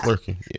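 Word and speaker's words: Flerken: Flurkin. I'm probably saying that wrong. Flurkin? Yeah. – Flurkin, yeah.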